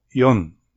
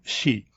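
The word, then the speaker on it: よん: El 4 figura como yon, no como shi, y el 7 como nana, no como shichi.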